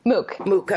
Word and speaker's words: MOOC: How did that experience, you going through the, and how do you say it, MMOC, I said, but how do you say it, MOOC? – MOOC.